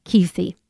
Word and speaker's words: Kuethe: His name is spelled K-U-E-T-H-E, Rick Keithy. So take a listen to his music, and that way you'll understand the depth of my friend Rick.